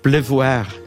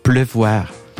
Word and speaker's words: pleuvoir: Com es pronuncia? – La pluie és el la substantiu, pluie. però pleuvoir és més complicat. L'infinitiu és ple, pleuvoir. – Pleuvoir.